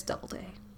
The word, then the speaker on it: Doubleday: Doobaday. General Doobaday. But it's Doubleday.